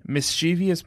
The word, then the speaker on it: mischievous: Yeah, and I would say mischievous. Mischievous is putting a syllable in that word that does not exist. And everyone who says that is now on notice.